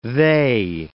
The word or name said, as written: they